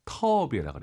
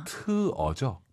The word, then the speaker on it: 特别: 선생님, 정말 많은 분들께서 터비에라 그래요. 터비에. – 아하. – 트어죠? – 네, 트.